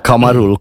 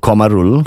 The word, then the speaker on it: Kamarul: Like my name Kamarul, Kamarul, Kamarul. My teacher call me Kumaru.